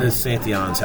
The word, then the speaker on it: Santillan: Tony Santillan. S-A-N-T-I-L-L-A-N or I-A-N. – I thought it was Santillan or something. – Santillan's how it's pronounced.